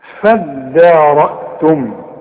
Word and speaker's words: فادارأتم: نفسا فادارأتم دي عايزه منك فن في النطق. ما تقولش فضى الدال الدال والمد بتاعها مرقق هتقول فدان.